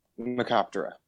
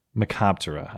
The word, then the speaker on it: Mecoptera: Terra is like wing. So think okay. Like pterodactyl. – Gotcha, yeah. So how do you pronounce the word? – Macoptera. – Macoptera.